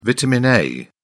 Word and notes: vitamin a: British English

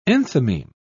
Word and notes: enthymeme: British English

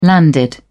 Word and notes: landed: British English

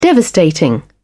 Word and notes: devastating: British English

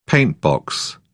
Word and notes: paintbox: British English

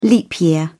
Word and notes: leap year: British English

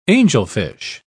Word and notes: angelfish: British English

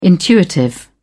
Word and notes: intuitive: British English

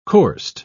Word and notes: coursed: British English

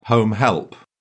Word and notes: home help: British English

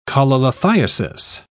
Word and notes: cholelithiasis: British English

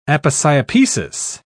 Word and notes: aposiopesis: British English

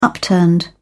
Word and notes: upturned: British English

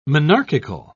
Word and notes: monarchical: British English

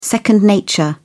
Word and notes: second nature: British English

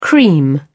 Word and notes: cream: British English

